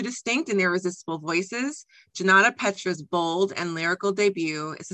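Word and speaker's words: Junauda Petrus: So this episode, we are going to be talking about The Stars and the Blackness Between Them, and that's by Janata Petrus. Okay, got it right. I was worried about getting her name right. So Janata Petrus.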